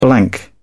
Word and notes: blank: British English

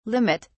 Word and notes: limit: British English

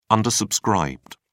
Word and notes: undersubscribed: British English

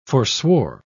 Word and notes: forswore: British English

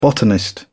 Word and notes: botanist: British English

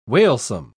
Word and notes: wailsome: British English

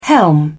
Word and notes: helm: British English

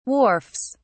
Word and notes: wharfs: British English

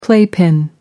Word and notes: playpen: American English

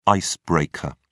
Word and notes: ice breaker: British English

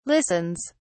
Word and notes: listens: British English

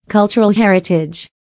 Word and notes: cultural heritage: American English

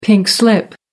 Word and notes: pink slip: American English

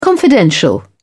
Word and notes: confidential: British English

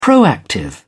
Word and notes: proactive: British English

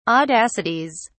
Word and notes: audacities: British English

